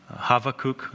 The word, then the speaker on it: Habakkuk: So we'll try to do Micah, Nahum, Zephaniah, and Habakkuk is how you say that.